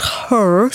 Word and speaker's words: Hurr: And there's another one called Her, spelled H-U-R-R, but I expect pronounced Her, which is more of a peer-to-peer lending site.